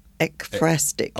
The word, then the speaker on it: ekphrastic: Is it E-P-H? Is that how you spell it? – Ekphrastic. – E-K-P-H-R-A-S-T-I-C.